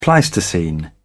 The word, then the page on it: pleistocene: British English